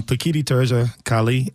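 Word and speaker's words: Pekiti Tirsia Kali: what is Bikini Teresi Kali? did I say that right? – Almost. Bikini Teresi Kali.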